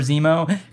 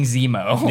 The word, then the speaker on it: Zemo: If not for Zemo, because I think that's what left a bad taste in my mouth. I'm like, this character is, He rhymes with Nemo. – Yeah. Finding Zemo.